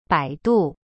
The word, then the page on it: 百度: bai du